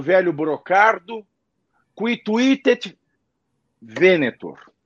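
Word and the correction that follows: videtur: Quituitet videtor, é videtor. eu falei errado.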